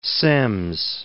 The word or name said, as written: Semmes